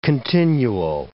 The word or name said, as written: continuo